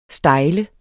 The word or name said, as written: stejle